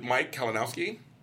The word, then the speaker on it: Mike Kalinowski: Is it Mike Kalinowski? – Yes.